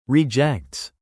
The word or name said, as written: rejects